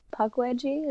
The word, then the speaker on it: Pukwudgie: Um, and I'm a Pukwudgie.